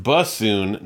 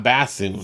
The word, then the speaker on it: bassoon: Janelle wins the prize for the correct pronunciation of this word, which is pronounced bassoon, not bassoon.